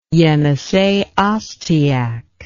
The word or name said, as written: Yenisei Ostyak